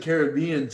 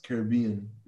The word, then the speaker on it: Caribbean: Everyone calls it Caribbean to the point where it's just accepted. But I think you're right. It's pronounced Caribbean based on the natives of the place.